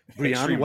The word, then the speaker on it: Brian: We've got, I think I'm pronouncing this right, Brain Altano. – It's Brian. Yeah. – Brian, it's French? – Yeah. – Okay. Brian, welcome.